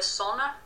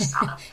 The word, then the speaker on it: Sanna: I think technically she should be a sonner, maybe, in the actual sort of Scandi dialect, rather than Scanner, Sanna, but you'd never get a northerner pronouncing it like that, so no. – she's a Sanna.